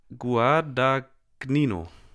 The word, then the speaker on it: Guadagnino: Und zwar Call Me By Your Name von, oh, oh jetzt wird es schwierig, Luca Guadagnino. Habe ich wahrscheinlich falsch Gua ausgesprochen. Wie, wie Gua? – Guad, Guadagnino. Guadagnino? Guadagnino. Gnino. – Gnino? Gnino. Mhm.